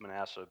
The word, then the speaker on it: Manasseh: Braun corrected me several times, but I call him Manasseh. Or Manasseh, I think is what I said. She said it's Manasseh.